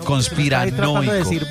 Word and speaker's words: conspiranoico: ¿Se dice así, conspirobónico? – No, señor, conspiranoico. – Conspiranoico. – Se dice conspiranoico. – Pensé que era conspirobónico.